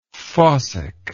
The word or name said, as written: fossicker